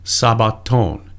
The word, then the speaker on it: sabbaton: This is Greek 4521, and the word is sabbaton, and that's the Greek pronunciation.